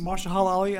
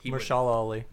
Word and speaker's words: Mahershala Ali: It's Mahershala Ali, I believe is how you pronounce it. He's incredible. – Mahershala Ali.